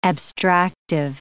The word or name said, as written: abstractive